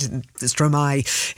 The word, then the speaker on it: Stromae: And I've just looked up, I've actually listened to the pronunciation because I've played Stromae, is how you say it, for many, many years.